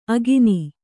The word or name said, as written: ಅಕ್ಕಣ್ಣಿ